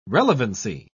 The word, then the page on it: relevancy: British English